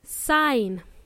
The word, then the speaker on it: sign: The word sign, guys, has a letter G in it. We don't pronounce the G, G sound. We pronounce it as sign.